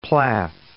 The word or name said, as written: Plath